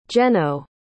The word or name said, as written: jeno